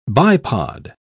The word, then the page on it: bipod: British English